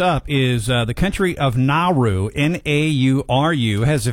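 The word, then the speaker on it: Naoero: President David Adang announced the change, which aligns with the local pronunciation Nauru. That's how you pronounce it. Nauru. Like N-O-W and then E-R-O, Nauru.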